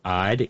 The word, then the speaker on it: Eide: My paternal grandmother was an Ide, E-I-D-E, pronounced Ide.